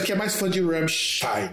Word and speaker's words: Rammstein: O A é mais aberto, é Rammstein.